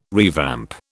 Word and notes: revamp: British English

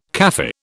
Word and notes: Kafir: British English

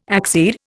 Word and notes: accede: Indian

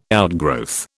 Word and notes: outgrowth: British English

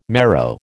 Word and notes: marrow: American English

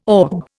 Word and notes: orb: Indian

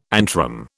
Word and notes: antrum: British English

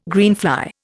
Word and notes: greenfly: Indian

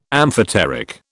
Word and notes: amphoteric: British English

